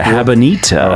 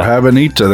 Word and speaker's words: Habanita: Wait, Habanita. – Oh, Habanita, there you go. – I kind of like that name. – I don't know. I feel like you're pronouncing it wrong. I don't think it's... – No, I think that's how it's pronounced.